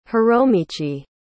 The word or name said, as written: hiromichi